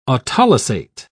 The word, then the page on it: autolysate: British English